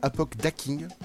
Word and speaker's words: Apokdaking: Tu as une chaîne qui s'appelle Apoc Dacking.